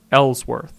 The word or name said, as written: Elsworth